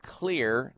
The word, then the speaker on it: Xlear: You can go to the health food store and pick up a nasal spray called Clear Nasal Spray. X-L-E-A-R is how you spell it. The X is for xylitol.